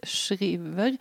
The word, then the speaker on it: Shriver: Vi har dryftat uttalet av efternamnet. Vi har länge sagt Shriver. Men nu har, från högre ort har vi inhämtat att man ska säga Shriver. Ja, vi får lära om.